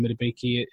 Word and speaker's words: Madubuike: Obviously, you've got Justin Madubuike. I butchered his name, but no, you nailed that. To be fair, that was really impressive.